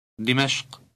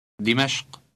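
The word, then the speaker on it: Dimashq (دمشق): Dimashq. Dimashq.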